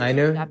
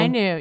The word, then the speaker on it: Ainu: Ainu? That's the Ainu. – Yeah, people. – The Ainu, yeah.